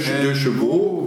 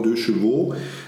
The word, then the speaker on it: Deux Chevaux: Chevaux kannst du so aussprechen, so wie der DS DSDS, die Göttin äh, heißt. Da heißt es halt einfach Deux, 2CV, Deux Chevaux, ja, was in der Schweiz auch dann eingebürgert wurde, Deux Chevaux, ja, haben sie es auch genannt.